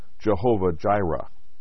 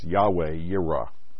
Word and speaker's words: Yahweh Yireh: The King James Version transliterates the Hebrew words as Jehovah Jireh. A better pronunciation here is Yahweh Yireh.